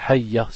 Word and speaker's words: hayya: Ils disent hayya ala salah, ça c'est faux. On dit hayya tout simplement, il n'y a pas d'alif à la fin. On ne prononce pas hayya, ça c'est pas ça. C'est juste hayya, c'est tout. Hayya, ça veut dire on insiste sur le ya. Hayya ala salah.